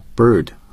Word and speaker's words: bird: 所以这一点呢，我想 会为大家读一下 bird 和 hurt 这两个单词，因为它能更好地展示这两个单词的在美式英语的读音是怎么样。 – bird bird hurt hurt Some speakers of British English do not sound the r in this word.